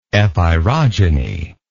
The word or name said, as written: epeirogenetic